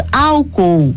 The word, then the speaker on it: álcool: A palavra álcool vem do árabe e em nossa língua a pronúncia permaneceu sem alteração, proparoxítona, com a antepenúltima sílaba tônica. Pronunciamos álcool, mas a palavra álcool no plural segue as regras semelhantes de lençol, lençóis, farol, faróis, caracol, caracóis.